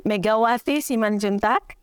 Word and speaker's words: Megawati Simanjuntak: So I'm Megawati Simanjuntak, usually.